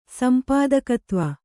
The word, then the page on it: ಸಂಪಾದಕತ್ವ: sampādakatva